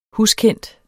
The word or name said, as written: huskendt